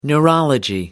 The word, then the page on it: neurology: American English